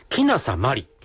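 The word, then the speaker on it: 鬼無里まり: それから、もう 2、 3年経つのかな、シャンソン歌手をやりますっていうことで、歌手名も、キナサマリ。これ、鬼がない里と書いて、キナサ。キナサマリ。マリはひらがなです。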